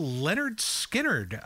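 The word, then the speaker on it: Lynyrd Skynyrd: Coming up next, we've got a little Leonard Skinner from their debut album.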